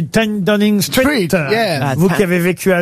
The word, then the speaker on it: Downing Street: Downing Street. – Downing Street. – Oui, je l'ai dit. – Downing Street. – Ten Downing Street, mais je voulais que ce soit bien prononcé.